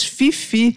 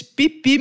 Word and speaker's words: Phi Phi: Eu sempre falei ilhas Fifi, mas não é, isso é um erro terrível, né? Ilhas Pipi mesmo.